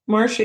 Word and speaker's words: Marcia: And Marsha is spelled like the Brady Bunch, MARCIA. Marsha is my nickname.